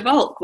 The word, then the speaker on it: Volk: And today I am talking to sculptor Patricia Volk.